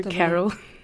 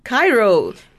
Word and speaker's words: Cairo: Yeah, you say Cairo, I say Carol. – Okay. – From Cape to Carol, from Cape to Cairo, however you pronounce it. – Okay, guys, it's Cairo, though.